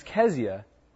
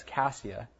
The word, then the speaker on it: Keziah: The second was Keziah, or another way to pronounce that is Cassia, and it means fragrance.